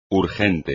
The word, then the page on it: urgente: espagnol